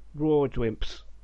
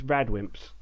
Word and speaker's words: Radwimps: I always used to read it as Rawdwimps, but it's Radwimps apparently.